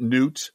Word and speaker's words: Gnut: And FYI, we're pronouncing it Newt. In the story, it's spelled G-N-U-T,